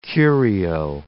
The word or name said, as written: curio